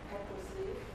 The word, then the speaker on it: ecclesia: Let's see, what's the pronunciation? Excuse me? – Ecclesia. – Yeah, ecclesia. That might not be a perfect pronunciation.